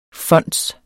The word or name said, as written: fonds